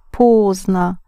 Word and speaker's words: поздно: You spell it поздно, but you pronounce поздно. Поздно, like pause. Yes, so if you pause, you will be late.